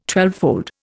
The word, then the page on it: twelvefold: Indian